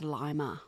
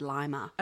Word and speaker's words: Lima: I think it's pronounced Lima. – Is it? – Yeah, because I saw a documentary the other day with Lima and then the people in the documentary said Lima.